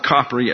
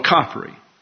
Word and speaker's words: Capri: And about halfway down the boot, you have an isle off the coast called Capri. Now, some people call it Capri, but Merriam-Webster does not.